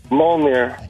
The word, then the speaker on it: Mjolnir: Mjolnir. – Yes! – Wow! – Whoa! – Did they say it in the first movie? – That's spelled Mjolnir. It's silent J.